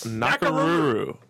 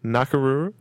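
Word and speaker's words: Nakoruru: Nakaruru. Samurai Showdown without the W. – Wait, say it for me again. – Nakaruru.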